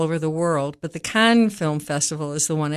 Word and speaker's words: Cannes: And I want to just mention that, by the way, the E-S at the end is silent. So it's C-A-N-N-E-S. But I've learned from Valerie, who's the French teacher, we say Cannes.